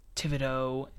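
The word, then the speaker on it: Thibodeaux: The New York Football Giants, I also give an A because Kayvon Thibodeau, I actually said that correctly, I'm surprised, nice and job.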